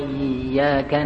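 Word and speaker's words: Iyyaka (إِيَّاكَ): Bahwa kalimat iya pada huruf ya itu ada syaddah. Ya, ada syaddahnya. Sehingga cara membacanya iya. Coba perhatikan, ada dua ya. Iyyaka. Kenapa begini? Karena kadang-kadang tanpa dirasakan oleh sebagian muslim, saudara kita, dibaca dengan tanpa syaddah.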